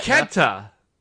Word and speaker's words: Kenta: It's Ketna. Kenta. No, this was Kenta.